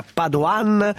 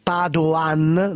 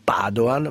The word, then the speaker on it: Padoan: Allora, il ministro dell'economia Padoan, ricordate che si pronuncia così, Padoan, non Padoan, ma Padoan, ha detto che ci saranno i soldi e gli 80 euro per in busta paga a maggio.